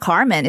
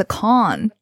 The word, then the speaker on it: Kahn: Oh, it's not a Carmen, it's a Kahn. – Oliver Kahn. – Oh, that's a German pronunciation, Kahn, not Carmen.